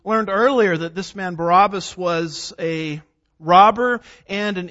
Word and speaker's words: Barabbas: We've studied a little bit about this man Barabbas or Barabbas. Depends on which syllable gets the emphasis, as I like to say.